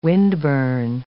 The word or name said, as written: windburn